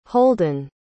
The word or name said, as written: holdyn